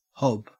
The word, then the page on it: hob: Australian English